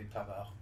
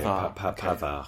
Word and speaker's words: Pavard: Pap, do we pronounce the D? – No, there's no E at the end, so we Pavard. – Okay. Pavard.